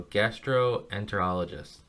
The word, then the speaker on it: gastroenterologist: oh, here we go, gastroenterologist.